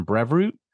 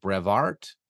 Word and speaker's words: Brevoort: Some examples, Tom Brevoort, which you keep pronouncing Brevoort, which I just did again. It's just how it comes out of my mouth. You've had him on the podcast. According to my research, it's more like Brevart.